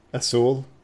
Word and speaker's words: azul: Yeah, the Spanish Z is pronounced like a soft C in front of the letter E or I, for example. For example, like azul. Azul. It's not Z, it's azul.